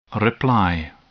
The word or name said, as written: reply